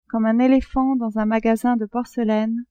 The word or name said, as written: comme un elephant dans un magasin de porcelaine